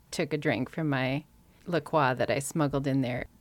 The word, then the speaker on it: LaCroix: It's pronounced LaCroix because it's from Wisconsin.